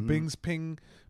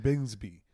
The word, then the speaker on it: Bisping: You got podcasts like Godfrey on it, Michael Bisping. – Yeah. – The UFC fight. I learned how to really say his name last night. I've been saying Bingsby. – I thought it was Bisping.